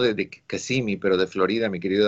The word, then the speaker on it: Kissimmee: Bueno, vamos a ver, claro, Kissimmee, así es como yo lo conozco. – Es Kissimmee. Es que en Florida hay nombres bien curiosos.